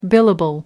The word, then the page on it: billable: American English